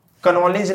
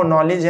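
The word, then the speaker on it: knowledge: इसको साइलेंट है जी कनॉलेज नहीं है वो नॉलेज है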